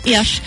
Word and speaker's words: Iași: That's how you say it, right? Iași. – Da.